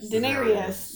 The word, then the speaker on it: Daenerys: apparently, because I have not watched the show, John and Daenerys, Daenerys. There we go.